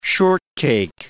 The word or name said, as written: shortcake